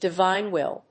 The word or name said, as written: divine will